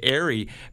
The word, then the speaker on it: Aerie: Well, the alternative is some of these other places like Aerie makes a great product.